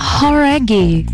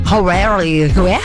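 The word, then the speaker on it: Jauregui: É Lauren Horaghi. Talvez você já tenha ouvido falar dela, ex-integrante do grupo Fifth Harmony. – Horaghi. – Horaghi. Então a gente tava falando errado, cara.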